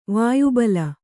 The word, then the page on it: ವಾಯುಬಲ: Kannada